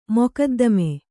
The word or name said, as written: ಮೊಕದ್ದಮೆ